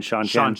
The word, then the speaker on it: Seanchan: Yeah, Sean, like Sean, S-E-A-N, Seanchan.